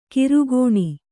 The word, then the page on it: ಕಿರುಗೋಣಿ: Kannada; kirugōṇi